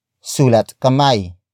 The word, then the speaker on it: sulat kamay: Sulat kamay is a combination of two words, sulat, which means writing, and kamay, which means hand.